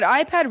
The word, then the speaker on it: iPad: You said iPod. I know you meant iPad, but iPad revenue being up 46%. – I was just pronouncing it like Spanish, iPod. – Right. Yeah, yeah, of sorry. Course. I know. Cause, well, you're iPad. – Yeah, iPad revenue, just in case it confused anybody.